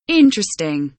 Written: interesting

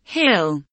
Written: hill

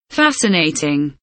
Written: fascinating

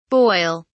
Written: boil

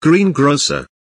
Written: greengrocer

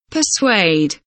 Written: persuade

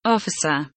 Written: officer

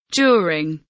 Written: during

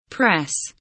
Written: press

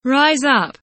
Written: rise up

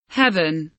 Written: heaven

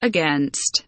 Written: against

